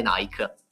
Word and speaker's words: Nike: Sì, veramente un tempio per celebrare Nike, scusami. Perché mi ha corretto che si pronuncia così. Nike